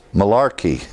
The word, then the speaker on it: Malarkey: This is titled The Boy Who Came Back from Heaven by Kevin, and I believe the name is pronounced Malarkey.